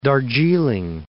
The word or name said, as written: Darjiling